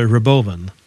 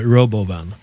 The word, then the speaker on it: Robovan: Fast han uttalar det Robovan. Men jag tror att man ska läsa det Robovan.